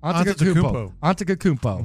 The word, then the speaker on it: Antetokounmpo: The Lakers made a move yesterday, and they claimed Kostas how do you say the Greek freak's last name? – Antetokounmpo.